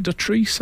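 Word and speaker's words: Dotrice: Edward Woodward's wife is Michelle Dutrice. Is it Dutrice? That's how we pronounce it. – Yes, that's correct.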